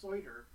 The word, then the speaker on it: Seutter: And it's important to note the author's name, Robert Seutter, that's S-E-U-T-T-E-R. Yeah. – Like the judge, but it's pronounced Seutter.